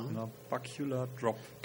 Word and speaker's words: Nabacular Drop: Nabacular Drop. Genau. Habe ich es richtig ausgesprochen? – Nabacular Drop, genau.